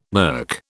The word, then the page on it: NERC: British English